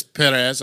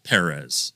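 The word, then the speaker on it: Pérez: he says Perez. I'm trying to roll my R's and do it right. – Well, you don't roll your R if it's just one R.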